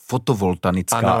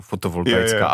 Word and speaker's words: fotovoltaická: No já tě dokonce pobavím tím, že spousta lidí, třeba našich klientů, to i špatně vyslovovala. Třeba říkali fotovoltanická elektrárna. – Ano, to jsem byl taky zvyklý, jasný. Jo. – A já vždycky tak nenápadně, vždycky, když to řekli, tak jsem jenom tak, víš, jako fotovoltaická.